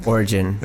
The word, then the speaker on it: Origen: the first person that kind of not only validated my concerned with that, but also pointed out that Origen? Is that how you say his name? – Origen.